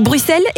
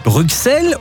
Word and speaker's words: Bruxelles: Bon, alors, une bonne fois pour toutes, est-ce qu'on dit Bruxelles ou Bruxelles ? – C'est vrai qu'il y a un X, mais ça se prononce pas.